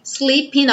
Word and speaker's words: sleeping: Sleeping on the job. Então, novamente, não vamos pronunciar o G do sleeping, vamos juntar o N no on.